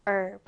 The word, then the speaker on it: herb: 네, 미국식으로는 자, 지금 발음교를 잘 보면 H 발음이 없어요. 그냥 herb입니다. herb. 자, 따라해볼까요? herb. herb. 자, 우리말로는 허브라고 하지만 영어로 미국식으로 발음할 때는 herb예요. 물론 영국식 영어로는 H 발음을 합니다.